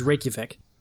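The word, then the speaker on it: Reykjavík: He was born in Iceland, in the capital, which I can't pronounce because it's a bunch Reykjavik? Of crazy letters put together. Reykjavik? – It's Reykjavik.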